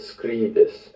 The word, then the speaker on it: scribis: Tu laboras et scribis et studes et dormis. – Did you remember that scribis has a short I sound?